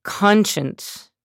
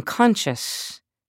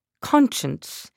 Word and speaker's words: conscience: Conscience. I was saying conscious. Conscience.